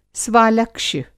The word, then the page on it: स्वालक्ष्य: Hindi